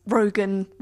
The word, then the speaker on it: Rogen: I do think that the signature laugh is what makes Rogen, Rogen.